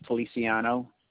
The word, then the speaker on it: Feliciano: Raul Feliciano. – Can you spell that for me? – R-A-U-L. Last name is spelled F-E-L-I-C-I-A-N-O.